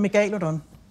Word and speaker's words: megalodon: Jeg plejer at bruge de latinske udtaleregler. – Mm. – Så hedder den megalodon. Men af en eller anden grund, så har vi danskere bare lyst til at sige megalodon eller megalodon. Og der må være et eller andet i det danske sprog, som gør, at det er derfor, man har lyst til at udtale det på den måde. – Så den korrekte er megalodon? – Hvis det skal være den latinske udtale, ja.